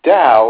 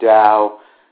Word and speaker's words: Tao: The Tao, or Tao, it's spelled T-A-O, and sometimes it's pronounced Tao.